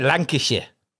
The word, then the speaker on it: Lancashire: Is that the right way to say it? Lancashire? – Yeah, Lancashire. Yes. Yeah. That's right. Yeah. That's the way.